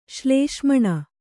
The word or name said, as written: ಶ್ಲೇಷ್ಮಣ